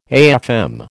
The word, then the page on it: AFM: American English